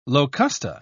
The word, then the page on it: locusta: British English